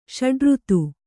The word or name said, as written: ಷಡುಋತು